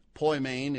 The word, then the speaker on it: poimen: Poimen is P-O-I-M-E-N, P-O-I-M-E-N, poimen, eugelistes, I'll spell it slowly, E-U-A-G-G-E-L-I-S-T-E-S, eugelistes, and it comes from euangelistes, it's a complicated word to pronounce, but it means, and I quote, to announce good news.